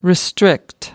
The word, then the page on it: restrict: American English